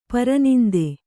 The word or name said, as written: ಪರನಿಂದೆ